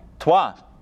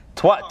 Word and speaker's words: Troyes: Troy's? I don't know how to pronounce it. – Trois. – There you go. Twa, T-R-Y-E-S. Yeah. Twa, so twa.